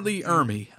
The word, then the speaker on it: Ermey: Yes, I said the name wrong. Man, geez Louise, I'm a horrible person. But yeah, R. Lee Ermey.